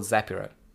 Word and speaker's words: Zapiro: I'm called Zaprio. You have to pronounce it well.